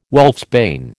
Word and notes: wolfsbane: American English

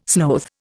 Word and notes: sloth: Indian

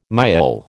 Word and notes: miaul: American English

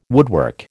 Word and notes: woodwork: American English

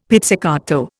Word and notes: pizzicato: Indian

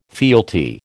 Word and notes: fealty: American English

